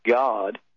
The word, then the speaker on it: Gad: but many other texts will have G-A-D there, and it's actually pronounced G-O-D or G-A-W-D, And it says here in Strong's 1408, a variation of the Hebrew number 1409, fortune, a Babylonian deity.